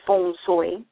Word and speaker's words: feng shui: the way it is pronounced in China, where it's more like F-O-N-G-S-O-Y, feng shui.